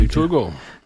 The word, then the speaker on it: Victor Hugo: Ja, Victor Hugo.